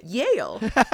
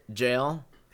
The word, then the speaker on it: Yale: Okay, Jughead's like, hey, Betty, I have an interview at Yale. – For this entire episode, I pronounced it in my head, jail, like J-A-L-E, because we say Jughead, Yughead.